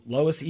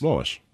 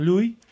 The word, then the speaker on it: Lois: How would you guys pronounce L-O-I-S? – Lois. – That's Lois for mm-hmm. sure? Maybe yeah. I've got French on my mind, and I'm like, Louis? – No. Lois.